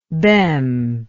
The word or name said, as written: bem